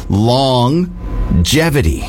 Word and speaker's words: longevity: So this I actually saw on Instagram, and it says, I was today years old when I realized you pronounce the G in longevity twice. And they wanted to know what other words in the English language are like this. So longevity. We say G. – Whoa. – Both ways you can pronounce a G, hard and soft.